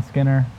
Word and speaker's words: Skinner: So I'm Sean, Sean Skinner.